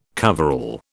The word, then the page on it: coverall: British English